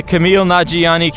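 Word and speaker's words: Kumail Nanjiani: Playing the Camille Nanjiani character from Everything, which is to say the guy from Silicon Valley.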